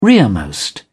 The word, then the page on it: rearmost: British English